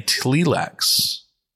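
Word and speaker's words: Tleilax: Plus their relationship with the Bene Tlilax, the Tlilax pronunciation comes from Frank Herbert's own mouth, from the Dune Trilogy soundbook.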